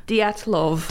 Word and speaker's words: Dyatlov: Dyatlov.